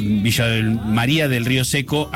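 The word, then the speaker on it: Villa de María del Río Seco: y ahí se están presentando su carta de presentación, porque de Villa de María del Río de Seco, Villa del María del Río Seco, así lo pronunciamos,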